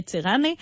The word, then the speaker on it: Tirana: Non, Albanie, en fait, Tirana, qui est prononcée en albanais Tirana, est la ville la plus peuplée d'Albanie.